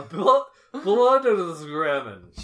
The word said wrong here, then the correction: Bildungsroman: Bildungsroman? – Yes, thank you.